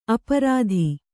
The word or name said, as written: ಅಪ್ರತಿ